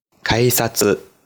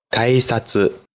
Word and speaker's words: 改札: 检票口，日语写作改闸。念作改札，改札。